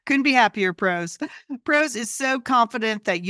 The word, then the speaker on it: Prose: And pros is spelled P-R-O-S-E.